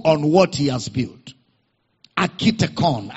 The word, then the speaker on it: architekon: Architekon. That's a Greek word. Architekon. It is spelled as A-R-C-H-I Architekon. T-E-K-O-N. Architekon.